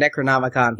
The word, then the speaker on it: Necronomicon: Necronomicon from Evil Dead.